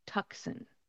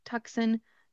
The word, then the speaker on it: Tucson: It is spelled T-U-C-S-O-N. You would think Tucson, that seems like a very understandable pronunciation. However, the C in this word is not pronounced. So instead, it is Tucson. Think of the word two or the number two and then son at the end.